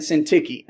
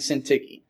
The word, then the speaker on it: Syntyche: But in the fourth chapter, there are two women mentioned, Euodia and Syntyche. It's weird spelling, so that's my pronunciation of such. Euodia and Syntyche.